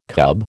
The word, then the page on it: cub: American English